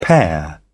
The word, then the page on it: pair: British English